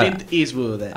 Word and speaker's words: Clint Eastwood: Pero bueno, el tema es Clint Eastwood, ¿lo dije bien? Ah. Clint Eastwood. Ahí está, lo tenés listo, perfecto.